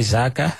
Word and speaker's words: Xaka: How would they pronounce it? – They would say Xaka.